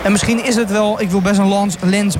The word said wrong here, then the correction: lans: breken. Een lans, ga ik het op zijn Frans uitspreken? Nee, het is ook een lans. Een lans breken, ja.